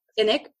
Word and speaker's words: Sinek: Simon Sinek? – Sinek. – Mm-hmm. Sinek, yes.